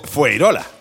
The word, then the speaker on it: Fuengirola: que se escribe Fuengirola y se pronuncia Fueirola.